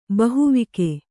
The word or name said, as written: ಬಹುವಿಕೆ